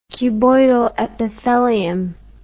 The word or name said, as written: cuboidal epithelium